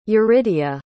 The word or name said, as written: yuridia